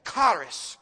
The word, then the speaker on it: charis: I'm trying to roll the R there. That's the way the pronunciation guide told me to say it. But charis, charis. You can say it that way. Charis, charis. I can't roll the R.